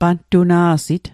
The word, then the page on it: pantuna'sit: Mi'gmaq